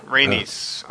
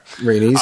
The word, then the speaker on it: Rhaenys: Is it... Rhaenys? I don't know how to pronounce her name. – Rhaenys? – Corlys' wife. – Yeah. – Rhaenys. Okay. – Rhaenys, I think, yeah.